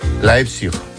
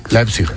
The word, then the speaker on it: Leipzig: ¿Lo he dicho bien? – Qué importante saber idiomas. – Tengo aquí a mi amigo que me lo dice. ¿Cómo se dice? – Leipzig. – Leipzig. Leipzig, ¿ves? – Leipzig, muy bien. – Venga, muy bien. – Es que claro, es alemán.